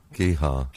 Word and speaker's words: Gigha: Well, G-I-G-H-A. Tartan... – G-I-G... – Oh, do you think it's Jihar? – Gihar. Yeah. Yes.